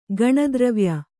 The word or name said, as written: ಗಣದ್ರವ್ಯ